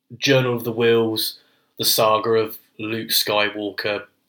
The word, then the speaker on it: Whills: And it's Wills, I think it's spelled like W-H-I-L-L-S.